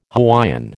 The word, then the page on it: Hawaiian: American English